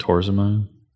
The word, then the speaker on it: torsemide: You could use loop diuretic, an NKCC2 channel inhibitor, or furosemide, tyrosemide, Bumex, that kind of stuff. – Isn't it torsemide? – Torsemide, I guess, would be the proper way to pronounce that.